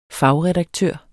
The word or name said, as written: fagredaktør